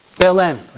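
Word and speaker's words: Belén: Belen.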